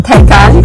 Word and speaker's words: Taycan: Taycan, that's how you pronounce it? – Yeah, Porsche Taycan.